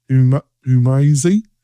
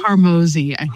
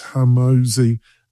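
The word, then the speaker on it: Hormozi: And we've got this geezer called Alex Humazi, is that how you pronounce it? – Harmozy, is how he pronounces it, yeah. – Yes, Alex Harmozy, very well-known influencer, business guru along.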